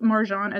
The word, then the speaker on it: Marjane: Author, I might hurt pronouncing this, but author Marjane Satrapi.